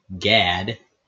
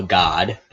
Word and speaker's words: Gad: Reuben, Simeon, Levi, Judah, Dan, Naphtali, Gad, well, God, depending on how you want to pronounce that.